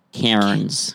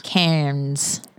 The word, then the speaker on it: Cairns: Spelled C-A-I-R-N-S. – But because that makes, yes, sense. – Canadians like to pronounce every single letter. – Yes, they do. – Why have an alphabet if you're not going to say all those all those letters? – Takes too long, mate.